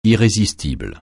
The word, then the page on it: irrésistible: francais